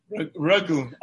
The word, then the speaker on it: Ragoone: It could be the next TikTok sensation with the Connie Magoon. – Ragoon, R-A-G-O-O-N-E.